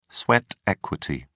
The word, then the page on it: sweat equity: British English